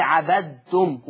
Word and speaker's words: عبدتم: فعشان كده اوعى تقول لي عبدتم وبين الدال، نطقك للكلمة دي ت خالصة تاء.